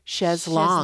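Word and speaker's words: chaise longue: In England, it's pronounced Chaise Long.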